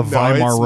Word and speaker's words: Weimar: The W's are pronounced as V's over there. – Yeah, that's why it's called the Weimar Republic.